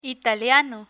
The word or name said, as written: italiano